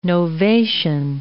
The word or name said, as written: novation